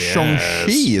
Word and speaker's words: Shang-Chi: Hey, it's been seconds and seconds since we talked about Marvel, and there was a Shang-Chi trailer this week, so we should probably discuss that. – It's a Shang-Chi trailer. – Shang-Chi, yes. – Sorry, I apologize. – I think it's Shang-Chi, is it not? – I think it is.